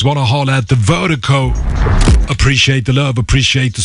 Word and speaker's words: Virdiko: And Vertigo is spelled V-I-R-D-I-K-O. That's V-I-R-D-I-K-O, pronounced Vertigo.